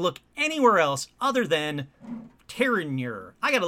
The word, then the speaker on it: Terenure: But let's see how you pronounce it in real life. Terenure. Oh, okay. It is Terenure. Terenure.